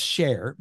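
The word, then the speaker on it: Sher: And I want to encourage everyone to go out and read Greg Scher's S-H-E-R. It's pronounced Share, but it's S-H-E-R, Sure, the way it's written.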